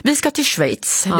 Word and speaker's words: Schweiz: Och du säger det helt perfekt. Schweiz, det är inte helt lätt. – Ja. Ja. – Ett ord med många uttal.